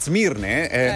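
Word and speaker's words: Smirne: In Turchia, in Turchia, a Zmirne, ora chiamata Izmir. Un tempo si chiamava Zmirne con la Z, si scrive con la S, adesso Izmir.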